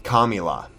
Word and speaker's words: Kamala: Lionel Timms was bailed out of jail in July thanks to the Minnesota Freedom Fund touted by Biden's running mate, Kamala Harris. Kamala Harris. Let's say her name right. You know, it's racist if you don't. Kamala.